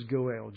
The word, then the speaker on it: Goel: But the word is Goel, G-O-E-L. Not Noel, but Goel. And that is the near kinsman. He is the Redeemer. Now, there are 3 basic functions of a Goel.